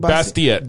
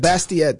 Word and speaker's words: Bastiat: Bastiat. – Bastiat, that's right.